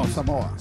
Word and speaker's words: Samoa: Yeah. Samoa. No, Samoa.